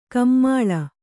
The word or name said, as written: ಕಮ್ಮಾಳ